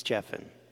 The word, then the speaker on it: Geffen: Okay, is it David Geffen? Have I been saying that wrong the whole time? David Geffen, right? It's Geffen.